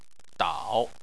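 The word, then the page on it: dao: tone 3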